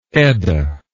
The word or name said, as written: Edda